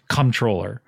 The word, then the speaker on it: comptroller: I heard that was pronounced comptroller. That the P is silent.